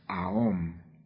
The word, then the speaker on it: Aum: Se escribe Aum y se pronuncia Aum.